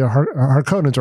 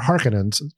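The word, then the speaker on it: Harkonnen: but the Harkonnens or Harkonnens, to use the Villeneuve pronunciation, are unreservedly ugly in ways that kind of give a perhaps appropriate face to colonialism.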